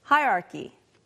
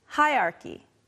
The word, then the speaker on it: hierarchy: Let's practice our pronunciation, starting with the first one, which, if you say it slow, sounds like hierarchy. However, most people say something like this, hierarchy, hierarchy. Sometimes the R kind of disappears, so repeat after me, hierarchy, hierarchy.